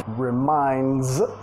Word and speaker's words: reminds: Reminds, sonido Z al final, reminds me of.